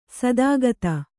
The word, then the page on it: ಸದಾಗತಿ: sadāgati